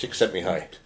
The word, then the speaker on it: Csikszentmihalyi: Csikszentmihalyi. – Well done. I've never known how to pronounce that. – I just committed. That's the secret. Just committed. – Csikszentmihalyi.